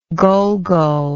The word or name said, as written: gulgul